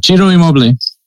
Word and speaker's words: Ciro Immobile: I'm going to try really hard to pronounce his first name right. Ciro Immobile. – Yeah. – Is that it? – Yeah.